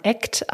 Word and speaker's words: ACT: Also in der Akzeptanz- und Commitment-Therapie spricht man ACT aus, weil es eben darum geht, richtig zu handeln und loszulegen.